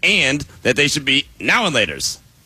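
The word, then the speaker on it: Now and Later: I say now and laters. You say now laters like it's all one word.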